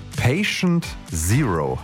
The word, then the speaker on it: Patient Zero: Patient Zero, habe ich das richtig ausgesprochen? – Ja, hast du.